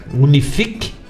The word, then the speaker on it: Unifique: Unifique, o C é mudo, Unifique Internet ou Internet Unifique, vai ver se tem na região.